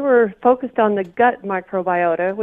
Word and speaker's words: microbiota: You use the word, and I think we're going to use the word microbiota. Is that have I pronounced right? Microbiota. – That's right. – Microbiota.